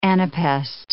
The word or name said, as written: anapest